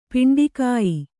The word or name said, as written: ಪಿಂಡಿಕಾಯಿ